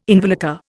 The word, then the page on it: involucre: Indian